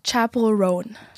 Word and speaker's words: Chappell Roan: Chappell Roan. – Chappell Roan.